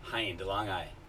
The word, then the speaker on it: Hein DeLongi: Hein DeLongi. Everyone screws it up. – Hein. – Okay, yeah, like behind.